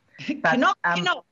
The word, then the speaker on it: Knokke: K-N-O-K-K-E. I would have said Knokke. – Knokke. Who's there? – Well, the man on the train can pronounce Knokke with a bit of a K. It wasn't silent, so we are too.